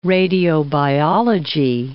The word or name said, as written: radiobiology